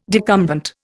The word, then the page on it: decumbent: Indian